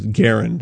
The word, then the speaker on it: Garand: And John Garand came up with Garand is the correct pronunciation of the man's name.